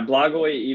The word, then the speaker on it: Blagoj: Yeah, Blagoj Ivanov.